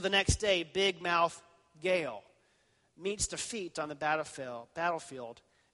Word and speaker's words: Gaal: The people of Shechem rally to a new man named Gaal, rhymes with Baal,